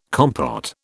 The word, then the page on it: compote: British English